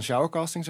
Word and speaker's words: Showercasting: Uh, eh, casting director en oprichter van Showercasting, zeg ik dat goed? – Ja, helemaal.